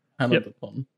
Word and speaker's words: Panel de Pon: Is it Panel de Pon? Is that how it's pronounced? – Yeah, Panel de Pon.